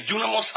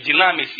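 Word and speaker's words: Дюнамос (δύναμις): «Дюнамос», оно немножко правильнее звучит из древнегреческого. Я все эти вещи смотрел, чем «динамис» или «динамис». Знаете, как говорят просто, или «динамос». Его употребляют по-разному.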